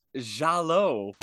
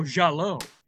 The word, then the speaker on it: giallo: So the Italians had giallo. – If you want to say it that way, sure. – How do you say it? – Oh, giallo. Yeah, the way you did it.